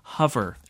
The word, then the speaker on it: Hover: But first, I think we need to hear from some friends at a company whose name is pronounced Hover.